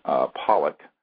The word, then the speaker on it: Pawelec: And what we're going to be talking about today is the testimony of a very important witness, William John Pawelek, is how you say his last name, although it's spelled P-A-W-E-L-E-C.